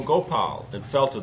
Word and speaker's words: Gopal: "O Gopal." The offering of these three syllables, Go, Pa, and La, was his way of properly honoring the Lord.